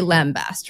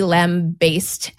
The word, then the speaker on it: lambast: you say lambast, right? I also... – Absolutely, and I've never heard it pronounced otherwise. – Apparently, it was originally lamb-based, and some people do still pronounce it that way.